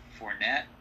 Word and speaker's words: Fournette: So they've got Josh Allen, and then they've got Leonard Fournette. Is that how you pronounce it? – Yep.